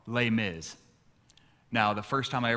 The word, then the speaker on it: Les Mis: It looks like it's spelled Les Miserables. Apparently, French people don't like all the letters, and they just say like the first few letters and then mumble the rest, right?